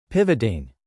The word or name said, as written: pivoting